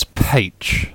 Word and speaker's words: Pécs: Is it pronounced Pécs? Have I already asked that already? – No, you haven't, but it's Pécs.